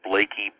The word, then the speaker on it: Blaikie: But the book is called For the Work of the Ministry, and the last name is spelled B-L-A-I-K-I-E, William Blakey, B-L-A-I-K-I-E.